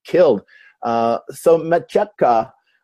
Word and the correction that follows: Matejka: How do you say it? – I think it's Matejka. – Matejka.